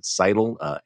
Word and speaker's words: Scytl: how do you pronounce it, Cytl, S-C-Y-T-L, none of which was true.